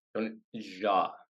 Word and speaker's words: Tony Jaa: Tony Jaa. Soft J, like jogging.